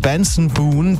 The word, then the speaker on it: Benson Boone: Jetzt Benson Boone, der so durchtrainiert ist, dass seine Bühnenshows regelmäßig zur Akrobatik-Show werden.